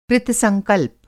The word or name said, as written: कृतसंकल्प